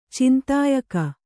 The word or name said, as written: ಚಿಂತಾಯಕ